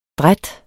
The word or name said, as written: dræt